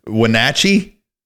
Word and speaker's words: Wenatchee: Wenatchee? – Yes.